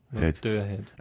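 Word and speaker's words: Motörhead: Motorhead. Aber man schreibt, also es sind Umlaute drauf, aber die werden nicht ausgesprochen. – Aha.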